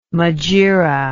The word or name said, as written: megaera